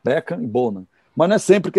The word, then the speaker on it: Bonham: mas o, o, jeito certo de falar o nome do baterista do Led Zeppelin é John Bonham. Esse H não fala.